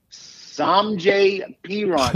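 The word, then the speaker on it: Samaje Perine: Maybe this Samjay Perine, P-E-R-I-N-E. How do I know how to pronounce his name? Because he's a Jets castoff.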